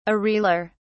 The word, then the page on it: areolar: British English